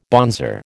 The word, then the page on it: bonzer: American English